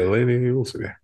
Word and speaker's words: Lainey Wilson: And this one's of a country music artist, late is it Lainey Wilson? Is that her name, Brandon? Am I saying it right? She's also in Yellowstone. Oh yeah, Lainey Wilson.